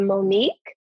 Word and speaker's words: Monique: I am at I am Anita Monique. So my name is spelled A-N-I-T-A. Monique is M-O-N-I-Q-U-E.